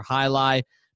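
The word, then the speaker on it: jai alai: It is Magic City Casino, and specifically the High. You spell that J-A-I. That's for High Lie, but it's not just High Lie.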